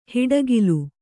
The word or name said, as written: ಹಿಡಿಗೋಲು